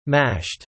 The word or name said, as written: mashed